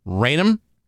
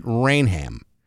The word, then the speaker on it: Rainham: Yeah, but uh, that that town's not too far from where I grew up, and uh, there are some people that pronounce it Rainham, some people pronounce it Rainham. And the people that pronounce it Rainham, their justification is have you ever gone to a restaurant and ordered the am and eggs? No, you have not. Okay. – So they say the H is uh, the it's H.